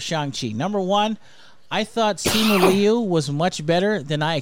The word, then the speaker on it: Simu Liu: It's Simu Liu.